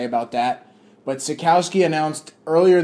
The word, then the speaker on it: Sitkowski: So what you're going to hear now first you're going to hear my interview with 2018 four-star quarterback Artur Sitkowski.